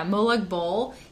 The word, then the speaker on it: Molag Bal: Yeah, Molag Bal.